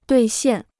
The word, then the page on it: 兑现: duì xiàn